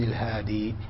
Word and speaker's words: الهادي: الهاد هكذا يكتبونه، والصواب أنه عند الوقف يوقف على الياء، العهاد والعاص، الصواب أو الأصوب أنه يوقف على ياء، وعند النطق وعند الوصل يخفف إلى كسرة. واضح؟ تقول عن يزيد بن الهادي، عن يزيد بن الهادي.